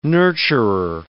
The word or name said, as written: nurturer